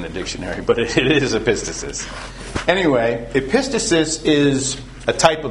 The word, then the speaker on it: epistasis: And this is an example of an often mispronounced word called epistasis. So when you hear someone say epistasis, make fun of them. It's epistasis. Actually, they might both be allowed.